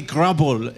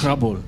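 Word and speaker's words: Gråbøl: And I think Sophie Grabol is right. On my left. Hello, Sophie. How are you doing? – I'm fine, thanks. Lovely to be here. Beautiful pronunciation. – Grabol. – Yes, you can move to Denmark anytime.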